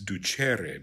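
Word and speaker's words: ducēre: Sorry, I said ducere because I was thinking of the infinitive. It's ducere because that's a long E, okay?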